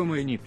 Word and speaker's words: майник: Запомните, что майник читается с ударем на «и» на Хэллоуин.